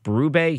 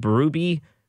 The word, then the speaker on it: Berube: Like, instead of saying Mike Berube, he said Berube, or Berube. He mispronounced Craig Berube.